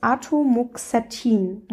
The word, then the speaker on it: Atomoxetin: Es gibt nämlich noch das Atomoxetin.